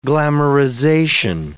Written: glamorization

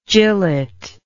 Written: jillet

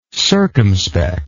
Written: circumspectness

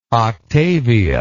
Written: octavia

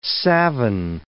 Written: savin

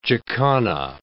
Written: jacana

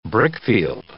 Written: brickfield

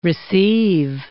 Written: receive

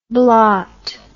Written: blart

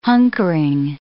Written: hunkering

